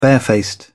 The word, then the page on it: barefaced: British English